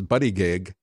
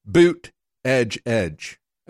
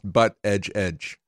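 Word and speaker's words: Buttigieg: It's spelled as if it was Buddy Gig, but and he is the first openly homosexual candidate for president. But it's actually pronounced, according to him, Boot Edge Edge. And they've actually printed up T-shirts to tell people that that Buddy Gig is actually pronounced Butt Edge Edge.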